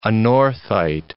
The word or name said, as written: anorthite